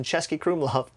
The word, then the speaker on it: Český Krumlov: One of my favorite meals was in Český Krumlov.